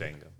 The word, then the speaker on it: Django: The D is the Django. – Yeah. – D is silent. – Django.